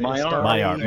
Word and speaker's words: Maiar: Is it Maiar? – Maiar. Was it Maiar? – Maiar. Sorry, Maiar, I mispronounced.